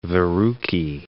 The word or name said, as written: verrucae